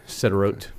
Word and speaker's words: Sderot: The, you spell it S D E mm-hmm. R O T, Sderot.